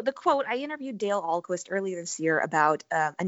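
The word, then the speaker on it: Ahlquist: So you heard this quote while interviewing Dale Alquist. Am I saying that right? – Yes.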